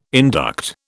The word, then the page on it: induct: British English